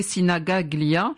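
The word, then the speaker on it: Sinigaglia: Si vous n'avez pas vu, regardez sur les réseaux sociaux, c'est le stade Giuseppe Sinagaglia, j'espère que j'ai bien prononcé, Mathéo. – C'est bien, Mathéo, c'est très bien.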